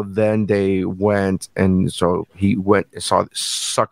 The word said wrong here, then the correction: sought: out this challenge. Is that how you say it? Sought out. – Sought.